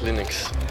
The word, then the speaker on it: Linux: I pronounce Linux. – 142. – As Linux.